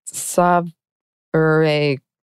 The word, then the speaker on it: sovereign: Aloud, she said, "Spell it for us, dear." S-O-V-E-R-E-I-G-N. Grin widening, Tilda called to him, "It's pronounced sovereign." "Sovereign," he repeated thoughtfully and again a second time. Then he inquired, almost suspiciously, "Are you sure? It's got a G in it."